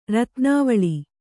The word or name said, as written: ರತ್ನಾವಳಿ